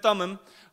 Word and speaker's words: Thummim: And if you look, um, if you look up what the Urim and the Thummim is, I know it's weird pronunciation there, but it's Urim and Thummim.